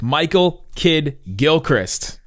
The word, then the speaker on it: Michael Kidd-Gilchrist: Pretty good length interview to talk one-on-one with Michael Kidd-Gilchrist.